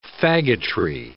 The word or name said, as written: faggotry